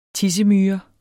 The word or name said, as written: TISSEMYRE